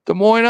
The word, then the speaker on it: Des Moines: Des Moines, Iowa. And the S is silent.